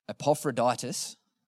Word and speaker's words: Epaphroditus: Paul's writing, the reason why Paul is writing is, I'm going to get this name wrong, Epaphroditus. Did I get that right, Reese? No, he did, yes.